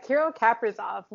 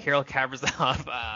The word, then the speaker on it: Kirill Kaprizov: Kirill Kaprizov. – Kirill Kaprizov.